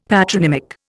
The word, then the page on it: patronymic: Indian